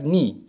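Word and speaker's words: ni (二): The sound a cat makes in Japanese is similar to 2, pronounced like ni in Japanese.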